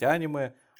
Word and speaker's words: аниме: А оно все вместе пересекается в аниме, или, как правильно говорить, аниме.